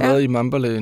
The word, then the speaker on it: Mbale: Så da du øh, tog til, nu skal jeg udtale det rigtigt, Mbale? Mambale. Mambale. Mambale.